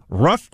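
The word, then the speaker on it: Ruff: Go to Rough Greens. It's R-U-F-F. So you got to spell it right.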